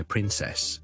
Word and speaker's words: princess: 萧叔叔自己嘅读音系 princess princess princess，亦都系一个非常之 common 嘅读音，系英式英文 princess。留意我个重音都系放喺个 print 度，但系后面嗰个响音咧，我就会用咗个 a 音，就唔系 e 唔系 princess 啦，而系 princess princess princess。